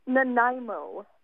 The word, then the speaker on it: Nanaimo: It sounds like Little Nemo to me. Is that the name of your town? – Nanaimo.